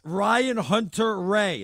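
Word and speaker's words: Ryan Hunter-Reay: Ryan Hunter Ray. Is that how you say his name? – You hit it on the head.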